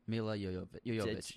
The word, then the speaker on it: Milla Jovovich: Mila Jovovich. I always say the name wrong. – Mila Jovovich.